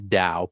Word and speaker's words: Tao: And I should note, I called it Tao, but it is Dao, pronounced Dao, spelled T-A-O. That's a little mental block that I always get wrong there. T-A-O of self, but Dao of self.